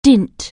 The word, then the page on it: dint: anglais